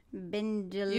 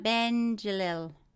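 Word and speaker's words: Bendjelloul: How do you say this? – Bendjelil. You give it come again? – Bendjelil. Bendjelil. – Malik Bendjelil.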